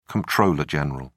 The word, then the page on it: comptroller general: British English